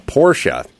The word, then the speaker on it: Porsche: I never have driven a Porsche.